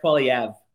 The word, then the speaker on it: Poilievre: Pierre Poilievre.